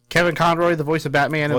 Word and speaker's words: Conroy: Suicide Squad Kill the Justice League will include Kevin Conroy. New release date. – Really? You can't say his name? – Conroy.